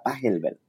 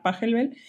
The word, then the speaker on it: Pachelbel: Me decías que así se pronuncia, ¿verdad? Pachelbel. – Sí, es un compositor alemán. Yo creo que eso es lo más real que creo que debe sonar Pachelbel.